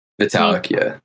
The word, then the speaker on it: Vitalik: Vitalik, yeah.